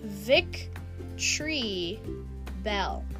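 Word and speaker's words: Victreebel: And then Victory Bell sounds like it would be Victory Bell, but it's Vic Tree Bell.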